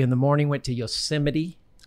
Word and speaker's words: Yosemite: You just learned it's Yosemite. – No, I'm... – Tucker taught him. – All right, guys. – Tucker's like, no, Dad, this is how you say it.